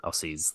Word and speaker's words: Aussie: Aussie's the worst. But it is spelt like that. It's spelt A U S S I E, but it's 100% Aussie.